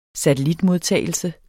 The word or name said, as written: satellitmodtagelse